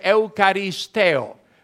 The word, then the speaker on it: eucharisteo: Is in the original language, you pronounce it Eucharisteo, the act of giving thanks.